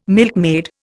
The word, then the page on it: milkmaid: Indian